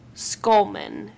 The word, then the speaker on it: Schulman: Skullman, and that's spelled S-C-H-U-L-M-A-N, so it's not two different Skulls. It's, like, Skull was his nickname, but it was also because, like, his last name started with Skull.